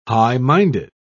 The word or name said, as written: high-minded